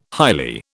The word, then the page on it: highly: British English